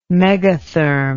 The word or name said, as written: megatherm